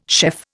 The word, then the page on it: chef: Indian